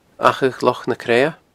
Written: Achadh Loch na Creadha

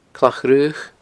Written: Clach Fhraoch

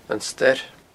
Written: An Stair

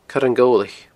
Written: coireangobhlaich

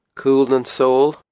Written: Cul nan Sabhal

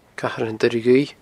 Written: Cathair an Doire Dhuibh

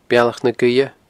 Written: Bealach na Gaoithe

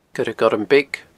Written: coiregormbeag

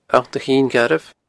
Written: Allt a Chinn Ghairbh

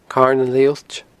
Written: Carn an Lethuillt

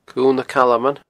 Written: Cuil nan Calman

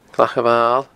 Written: Clach a Mhail

